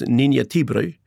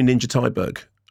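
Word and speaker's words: Ninja Thyberg: I interviewed her and her name in Sweden would be pronounced Ninja Tibri. But I said, how would you like me to say it? – Do that again. – Ninja Tibri. – I like that. – And she said, no, call me Ninja Tyberg. And I said, oh, really? Why? She said, well, because I prefer it to be said like that.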